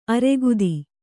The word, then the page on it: ಆರಾಘಾತ: ārāghāta